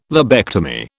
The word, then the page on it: lobectomy: British English